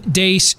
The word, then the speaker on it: Deace: You can like us on Facebook and follow us on Twitter at Steve Dace Show. Last name is spelled D-E-A-C-E.